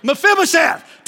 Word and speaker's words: Mephibosheth: Meth, I can't even say his name. Mephibosheth, tell him, grab him and say, come on, Mephibosheth, you coming out.